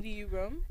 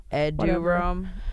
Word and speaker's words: Eduroam: You said uterome? – Yeah, how do you say it? – Eduroam. – Oh. – Eduroam.